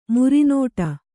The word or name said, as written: ಮರುನುಡಿ